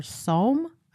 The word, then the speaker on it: Somme: Abbeville was named for a town in France, which is located on the river Somme. I think that's how you pronounce it, S-O-M-M-E.